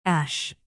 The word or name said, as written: ash